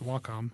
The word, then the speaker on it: Wacom: But there's a thing called the Cintiq that a company called Wacom It's pronounced Wacom.